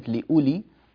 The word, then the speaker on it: لأولي: في قوله تعالى لآيات لأولي الألباب، نتنبه إلى مد البدل، وأن كلمة لأولي الواو فيها لا تنطق أبدا، لا تنطق أبدا.